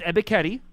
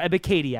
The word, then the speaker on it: Ebiketie: And uh, last question for you guys: What do you think about that Penn State edge rusher uh, Arnold Ebiketie? I probably butchered his last name, but uh, for the Giants, possibly at 36. – Uh, it is Ebekety or Ebikady, actually, technically.